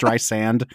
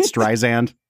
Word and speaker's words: Streisand: how it's pronounced Streisand, not Streisand.